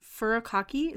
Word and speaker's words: furikake: Like furikake, is that how you say it? – Yeah.